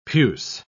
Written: puce

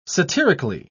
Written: satirically

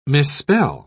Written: misspell

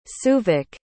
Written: souvik

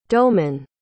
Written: doughman

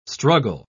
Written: struggle

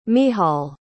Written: michal

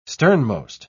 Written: sternmost

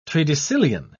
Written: tredecillion

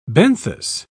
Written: benthos

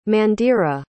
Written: mandira

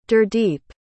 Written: gurdeep

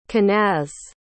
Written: kenaz

